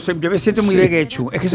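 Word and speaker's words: Getxo: En Guecho, que nunca sé cómo se pronuncia. – ¿Guecho? – Guecho, yo creo que lo pronuncio bien.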